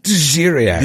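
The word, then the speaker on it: D'ziriak: Let's talk about this Djiriak. Am I saying that right? – Djiriak.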